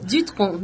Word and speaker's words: Dutronc: pronúncia aqui? Sim, Dutronc, Dutronc.